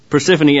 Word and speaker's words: Persephone: He was believed to have been born from the union of Zeus and Persephone. Persephone. There we go. I'll get it. I'll actually put the enunciation on the correct syllable. Persephone,